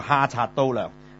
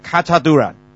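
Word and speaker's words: 卡 察 都 亮 (Խաչատրյան): 咁大家咧啱啱聽過嘅咧，就係、是、亞美尼亞一個作曲家叫做哈察都亮嚇，咁、就叫做我真係即係問過個導遊，佢話發音我話嚇咁得意嘅，原來一模一樣咁就係嘅，就係卡察都亮。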